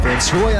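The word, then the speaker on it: Van Scoyoc: Van Scoy added to the roster earlier this week. It is actually spelled V-A-N space 1-0. McIntosh takes down the middle 1-1. S-C-O-Y-O-C. Essentially, the O-C is silent.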